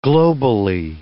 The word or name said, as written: globally